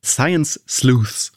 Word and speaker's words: Science Sleuths: Science Sleuths.